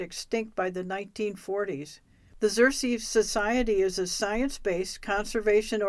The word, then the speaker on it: Xerces: Our name, which is pronounced Xerces,